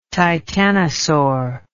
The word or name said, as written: titanosaur